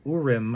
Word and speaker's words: Urim: They would have this Urim, I keep mispronouncing that, Urim and Thummim, embedded within their breastplates, and they would use those to determine what God's will was for a situation or making a decision.